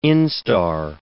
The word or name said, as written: instar